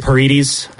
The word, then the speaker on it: Paredes: Did I say that right? – Paredes. – God, Paredes in the USA.